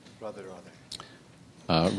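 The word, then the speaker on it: Radhe Radhe: Rade Rade, am I saying that correctly? – Rade Rade.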